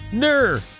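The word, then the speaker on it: gnurr: And that term is NURR, with the silent G. G-N-U-R-R, NURR.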